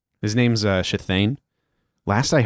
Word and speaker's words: Shithane: Is it S-H-I-T-H-A-N-E? – I, you know what? I've never written it down. – Shathane? – That it's pronounced Shathane, so okay. Write it however you need to write it. – Okay, S-H-I-T-H-A-N-E.